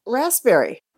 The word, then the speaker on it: raspberry: What flavor of ice cream has a silent P as its fourth letter? – P, fourth letter. Okay. Bum, bum, bum. Raspberry.